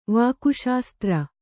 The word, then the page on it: ವಾಕುಶಾಸ್ತ್ರ: Kannada